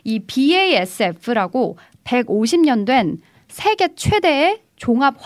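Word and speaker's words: BASF: 사실 이게 BASF라고 읽는 것이 어. 현재에서는 맞습니다.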